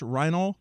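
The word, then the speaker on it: Reinoehl: Michael Forrest Reinol, I believe is how the last name is pronounced, Reinol. It's R-E-I-N-O-E-H-L.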